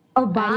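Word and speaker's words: aubade: Obad? – You pronounce it as Obad. Obad is a French word. It literally means morning serenade.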